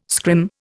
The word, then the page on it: scrim: Indian